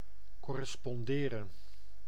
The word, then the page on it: correspond: [ˌkɔɹ.ə.ˈspɒnd]; /ˌkɒɹ.ɪ.ˈspɒnd/; /ˌkɔːɹ.ɪ.ˈspɒnd/